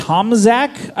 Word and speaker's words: Tomczak: But her name is Grace T- Tomczak, I believe, is how you would pronounce it.